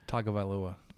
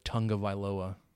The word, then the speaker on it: Tagovailoa: I was like, he actually said it right in the pregame, and then very, um, very quickly, kind of heat of the moment, devolved into the Tunga Viloa, Tonga, tongue.